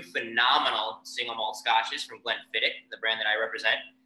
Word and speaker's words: Glenfiddich: It is Glenfiddich.